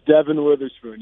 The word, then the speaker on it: Devin Witherspoon: How do I properly say his name? – It's Devin Witherspoon.